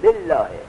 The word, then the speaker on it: بالله: ترقیق یعنی خیلی سبک تلفظ میشد بالله،